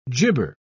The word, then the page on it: jibber: British English